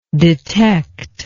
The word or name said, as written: detectibility